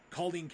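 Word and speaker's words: Culzean: But I did get to see a number of the key locations from there, including Culleen Castle, which I believe that's the proper pronunciation. I think the Z is silent in there.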